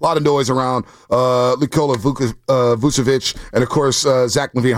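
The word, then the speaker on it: Vučević: You pronounced it correctly. It's Vucevic.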